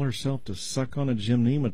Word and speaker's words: gymnema: One thing that could work, there is an herb called gymnema that's spelled G-Y-M, N as in Nelson, E-M-A, gymnema.